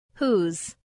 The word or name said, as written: whose